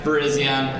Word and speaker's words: Virizion: So some of you guys said it right. It's Cavalion, Terrakion, Virizion, and Keldeo.